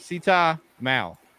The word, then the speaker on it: Sita: Is it Sitha? Am I saying that right? – Sita. – Dang it. Yeah, the H is silent. – All right. Sita Mal, correct? – Yep.